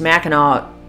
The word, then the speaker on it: Mackinac: I found out through this Facebook page that I'm part of, Addicted to Mackinac, that all residents really don't like it if you spell it with a W. They want it with a C because that is how it is pronounced.